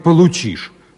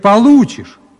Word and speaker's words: получишь: Николай Владимирович, нельзя говорить «получишь». Надо говорить «получишь». Вы неправильно ударение ставите.